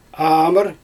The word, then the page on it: aamyr: Xyzyl